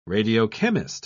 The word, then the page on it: radiochemist: British English